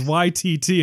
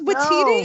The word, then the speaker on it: Waititi: It's not YTT. It's Waititi. No. I'm so sorry, Mr... – Taika Waititi. – Like, he's YT because he's so white. This person of color. I'm done. – Yeah. All right, we're revoking your ally card. – Walking that back. – You're no longer an ally. You are at is best white- Switzerland. – Wait, how do you pronounce it seriously? – I think it's Waititi.